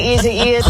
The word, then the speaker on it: Isaias: Hurricane Isaias is on the way. – Here's Hurricane Aisha. – This is as Isaias approaches. – Hurricane Iggy Azalea is... – Hurricane Isaias.